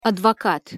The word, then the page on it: advokat: Russian